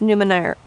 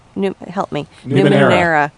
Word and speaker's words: Numenera: The system was Numenera. – Numenera. – I'm trying to put extra syllables in it. Like Randy Newman era. – Got it. – I used to call it Numenera.